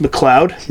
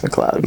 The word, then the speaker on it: McLeod: That's you say that's pronounced McLeod? – Yeah. – It's L-E-O-D. – Yeah, it's McLeod. – McLeod. Well, McLeod, fucking spell your name the right way if you're going to pronounce it that way.